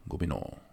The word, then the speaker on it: Gobineau: Habe ich das richtig ausgesprochen? Gobineau, ne? Gobineau. – Ja. Ich würde es ähm, auch ungefähr so aussprechen.